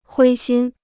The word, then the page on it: 灰心: huī xīn